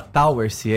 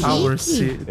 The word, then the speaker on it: Towersey: É, se escreve Towessey, mas se fala Towersey, é inglês. – Towersey.